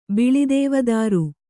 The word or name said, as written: ಬಿಳಿದೇವದಾರು